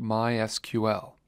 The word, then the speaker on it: MySQL: MySQL is a popular open source relational database management system, and yes, it is in fact pronounced MySQL.